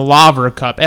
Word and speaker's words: Laver: The Laver Cup, LAVER, Laver Cup.